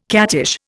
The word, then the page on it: cattish: Indian